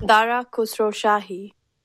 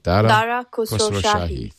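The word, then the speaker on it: Dara Khosrowshahi: Dara Khosrow Shahi. – Khosrow Shahi. Dara Khosrow Shahi. Thank you. – Dara. – That is a YouTube video. Dara Khosrow Shahi.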